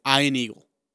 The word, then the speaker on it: Ian Eagle: It's Ian Eagle. – Yeah, like Eagle Eye.